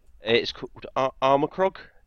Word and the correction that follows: Armikrog: Oh, Army Krog.